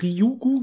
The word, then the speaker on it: Ryugu: Der Asteroid, wie heißt Ryugu, glaube ich, wird er ausgesprochen, Janis? – Genau.